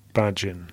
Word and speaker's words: Bajin: My understanding is that it's Bajin.